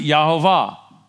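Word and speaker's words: Jehovah: And so then there's the four letters, no consonants, which says, and we pronounce it Yahovah.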